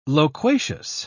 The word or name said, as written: loquacious